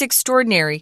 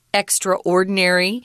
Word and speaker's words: extraordinary: She's extraordinary. I have heard people pronounce this word extraordinary, but I think the preferred pronunciation is extraordinary, which means you're not just an average person.